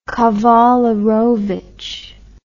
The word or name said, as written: kawalerowicz